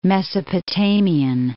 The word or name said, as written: Mesopotamian